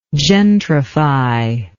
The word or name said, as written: gentrifying